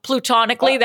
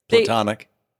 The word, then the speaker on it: platonic: Oh, plutonically, that was another one. You know, they're... – Platonic.